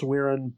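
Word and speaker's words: Wirrn: And by the way, if you're wondering at home why I keep going on about the pronunciation, it's because it is pronounced Wirin, but Martyr for some reason gives it an extra R, which is just strange.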